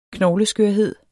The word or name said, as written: knogleskørhed